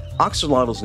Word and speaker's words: axolotl: Axolotl's traditional pronunciation, axolot, originates from a combination of Nahuatl words of water, at, and xolot, which can translate to dog, companion, page, or even has a reference to unusual or abnormal.